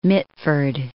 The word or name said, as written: Mitford